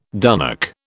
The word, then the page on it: dunnock: British English